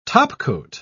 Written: topcoat

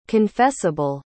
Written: confessable

